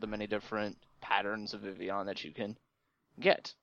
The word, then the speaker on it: Vivillon: He was constantly pronouncing it the American way, Vivillon, which is how we would read it. But we forget that there is some French pronunciation that we have to incorporate into it as well, which is actually Vivian, and it comes from the combination of two words, vivid and papillon, which is the French pronunciation of butterfly.